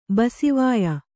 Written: ಬಸಿವಾಯ

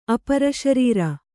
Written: ಅಪರಶರೀರ

